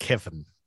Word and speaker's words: Cefn: I think it's pronounced Kevin, like Kevin.